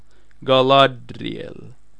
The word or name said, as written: Galadriel